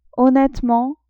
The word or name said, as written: honnetement